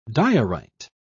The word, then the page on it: diorite: British English